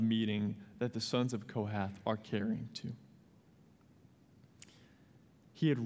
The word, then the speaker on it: Kohath: as the camp sets out after the sons of Kohath is how you say that, shall come to carry these, but they must not touch the holy things, lest they die.